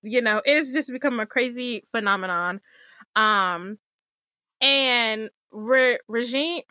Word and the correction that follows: Regé-Jean: Regé-Jean Page is the fine, beautiful British black man that stars on the show.